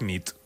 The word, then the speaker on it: nit: Y noche es nit. – Muy bien. – Bona nit. Bona nit, es verdad. Bona nit.